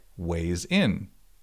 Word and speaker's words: Wayzn: Although, if you have a sliding glass door to the outside, you should definitely check out Wayzn, spelled W A Y Z N, and pronounced like weighs in.